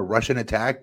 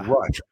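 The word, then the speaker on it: Rush'n Attack: But we always would pronounce it incorrect. It was Rush. R S H dash N dash Attack. – Right. – Yeah. And we would say it like Russian Attack, like the Russians are attacking.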